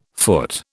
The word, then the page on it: foot: British English